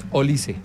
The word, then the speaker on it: Olise: La correcta pronunciación es Olise. – Ok, no Olise. – No es Olise, Olise. Sino Olise.